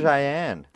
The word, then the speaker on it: Cheyenne: Cayenne. – It's Cheyenne.